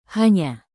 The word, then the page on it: hanya: indonesian